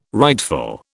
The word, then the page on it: rightful: British English